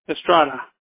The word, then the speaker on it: Estrada: Okay, J-U-B-E-N-T-I-N-O? – Yeah, J-U-B-E-N-T-I-N-O. – All right. And then the last name, sir? – Estrada, E-S-T-R-A-D-A.